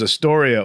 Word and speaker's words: Astoria: But there's Astoria, Oregon, but they pronounce it Astoria. – Astoria. – And I thought everybody was just being jerks. Like, oh, it's good to be here in Astoria. I'm like, yeah, he's really giving them the screws. Hey, we Astoria. That's kind of mean to say. They're like, no, that's how we pronounce it here.